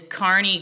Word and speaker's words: Kearney: My Art account is Carney Creative, and it's spelled, you know, like my name, K-E-A-R-N-E-Y.